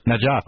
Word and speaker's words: Najaf: How do you pronounce it? – Najaf. – Oh, that was good the first time.